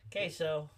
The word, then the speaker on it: queso: And I'm like, I was like, I know it's a Q, but it's pronounced with a K. Queso.